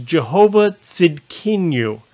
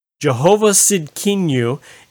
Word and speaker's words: Jehovah Tzidkenu: This is a tougher one to pronounce. This one's Jehovah Tzidkenu. Jehovah Tzidkenu.